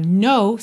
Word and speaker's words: know: No, atenção que o K inicial é mudo, right? A palavra know, saber, conhecer, K-N-O-W, soa igual a palavra know, não, N-O, right? Então, oh baby, make me know you love me, love, não é love, love me so.